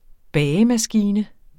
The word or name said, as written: bagemaskine